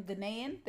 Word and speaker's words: Ghanaian: To a Nigerian Ghanaian, did I say that right? – Yeah.